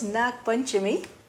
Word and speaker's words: Nag Panchami: Je spreekt het uit als Nag Panchami.